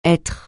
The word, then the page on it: être: francais